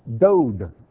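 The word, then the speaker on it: Dod (דוד): This word David can also be pronounced Dod, D-O-D. You don't change the spelling at all, you just change the pronunciation. In printed Bibles, or printed Hebrew, they'll sometimes put a dot, which is an extra marking to show you it's pronounced Dod.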